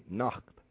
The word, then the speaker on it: Nacht: N-A-G is Nach. Kui Anach. – Kui Anach. – Kui Anach. – And in German is spelled N-A-C-H-T. Nacht. They say Nacht. We have a more smoother saying. They have a more ach.